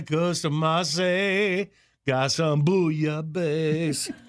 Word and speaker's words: bouillabaisse: It's National, I thought it was Billy Bassi Day, but you say that's a French word, and you pronounce it bouillabaisse. – Bouillabaisse. – Is it bouillah? You don't pronounce L's? – Yeah.